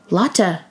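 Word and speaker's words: Lotte: The Lot Duty Free Family. Oh, I'm sorry. I am pronouncing that incorrectly. The Lotta Duty Free Family Concert was Sunday, and BTS performed three songs: Life Goes On, Telepathy, and Dynamite.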